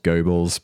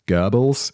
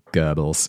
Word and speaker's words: Goebbels: This pronunciation is weird. I'm definitely going to screw it up. It's spelt Goebbels, but it's Goebbels. I know that's not the German pronunciation, but it's the accepted English language pronunciation, Goebbels.